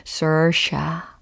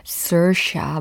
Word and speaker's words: Saoirse: Sersha. Sersha 막 이러면서 연습 많이 했습니다. – S A O I R S E. – Too many vowels. How is that Sersha? – 아 그래요.